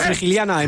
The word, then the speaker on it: Frigiliana: Frigiliana en Málaga, sí. – Sí, pues esto, yo sí, lo he dicho sí, bien, sí. lo he dicho bien.